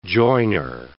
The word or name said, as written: joiner